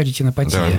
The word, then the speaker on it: ретинопатия: Я правильно ударение поставил, да, ретинопатия? – Да, все правильно.